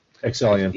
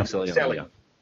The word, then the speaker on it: Excellion: Is that pronounced Excellion? – Excellion. – Yeah. Excellion.